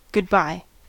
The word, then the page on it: goodbye: American English